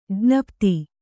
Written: ಜ್ಞಾಪಿತ

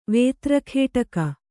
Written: ವೇತ್ರಖೇಟಕ

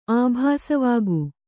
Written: ಆಭಾಸವಾಗು

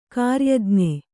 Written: ಗಿರಿಯಜ್ಞ

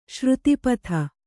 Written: ಸರಿತ್ಪತಿ

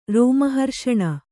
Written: ರೋಮಹರ್ಷಣ

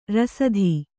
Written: ರಾಸ್ತಿ

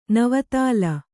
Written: ನವತಾಲ

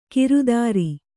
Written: ಕಿರುದಾರಿ

